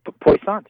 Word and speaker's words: Poissant: Yep, Poissant. Yeah, that's it.